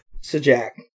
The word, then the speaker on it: Sajak: Which is on ABC, which is owned by father company Disney, and is hosted by none other than Pat Sajak, which rhymes with hijack.